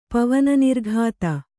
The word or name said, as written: ಪವನನಿರ್ಘಾತ